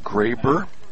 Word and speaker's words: Greber: Spelled G-R-E-B-E-R, pronounced Graber.